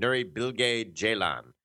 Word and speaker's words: Nuri Bilge Ceylan: The film by Nuri Bilge Ceylan. I've learned how to pronounce his name properly. – Wow, I've always said it wrong. Nuri Bilge Ceylan.